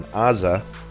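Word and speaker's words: Aza (עזה): Of course, here in Israel, the war still rages on down in Gaza, in Aza. That's how you say it in Hebrew.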